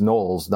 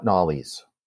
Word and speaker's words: Knollys: So it's Knowles, not Knollys.